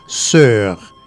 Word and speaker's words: sœur: Ça se prononce sœur. Sœur, ok. Ça s'écrit S-O-E-U-R. S-O-E collé U-R. Ah, répète pour moi. S-O-E collé U-R. – S-E-E collé U-R.